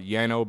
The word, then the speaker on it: Jaano: But I do want to shout out my homies, the Jano Band. You can check them out there on YouTube. I think it's actually pronounced like Yano Band, but it's spelled J A A N O Band.